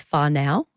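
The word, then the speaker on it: whānau: Thank you to those who've let me know that the correct pronunciation for family in Maori is whanau.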